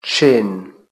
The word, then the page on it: chin: British English